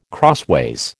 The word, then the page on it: crossways: American English